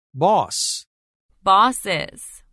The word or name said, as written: Boss – Bosses